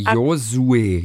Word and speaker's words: Josué: J-O-S, U und dann Accent aigu. – J-O-S. – J-O-S wie Josué.